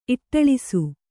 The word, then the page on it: ಇಟ್ಟಳಿಸು: Kannada; iṭṭaḷisu